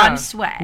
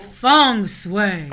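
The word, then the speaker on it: feng shui: Fun sway. – No, you still said it wrong. – Oh, but never mind. It is fun sway. – It's not fun sway. – It's feng sway.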